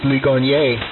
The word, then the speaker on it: Ligonier: We should look it up in the dictionary, and maybe we could decode its it's true pronunciation, just like you live near Ligonier, and yet the correct pronunciation that we learned in school in our textbooks was Ligonier. In that case, the French.